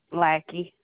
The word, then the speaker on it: Lackey: Lackey. – Spell that for me, please. – L-A-C-K-E-Y.